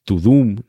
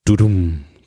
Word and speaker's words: Tudum: Y evidentemente esto no se pronuncia Tudum ni Turum, sino que se pronuncia Turum, que es ese sonido icónico del comienzo de las eh, producciones de esta plataforma.